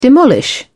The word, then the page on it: demolish: British English